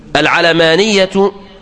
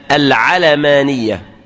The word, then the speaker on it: العلمانية: والصحيح أنها تنطق بفتح العين واللام العلمانية، ولا تنطق بكسر العين العلمانية، فهي ليست نسبة إلى العلم، وإنما هي نسبة إلى الدنيوية.